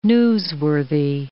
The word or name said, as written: newsworthy